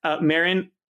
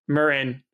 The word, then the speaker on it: Marin: Marin, California. Did I say it right? – Marin.